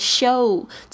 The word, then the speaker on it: show: 这个 show 这边的 o w 是发 o 的这个发音，它也是一个双元音的这个形式，所以你要发的很全，你不要就是 show show，而是 show show 这个 u 的声音呢，你要稍微要表示出来。